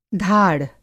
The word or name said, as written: धाड़